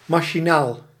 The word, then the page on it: superficiel: [sy.pɛʁ.fi.sjɛl]